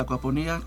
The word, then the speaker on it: acuaponía: Bueno, muy bien, la acuaponía, con acento en la I, ¿sí?